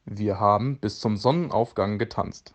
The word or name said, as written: bis zum Sonnenaufgang